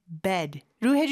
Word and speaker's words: bed: 이게 중요한 게 bad, 나쁜의 bad하고 발음이 좀 헷갈릴 수가 있어요. 그래서 나쁜은 bad, 입을 좀 더 크게 벌려주고 침대는 bed, 에 발음으로 좀 더 눌러주는 느낌으로 해주시면 됩니다. I'm heading to bed, I'm heading to bed.